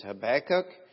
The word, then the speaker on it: Habakkuk: And the best way I know how to pronounce it is Habakkuk.